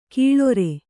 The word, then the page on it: ಕೀಳೊರೆ: Kannada